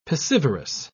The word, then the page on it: piscivorous: British English